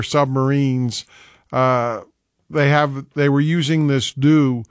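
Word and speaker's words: dhow: Now, if you've never heard of a DOU, D-O-U is not how it's spelled. It's actually spelled D-H-O-W, D-H-O-W, but it's pronounced DOU like D-O-U.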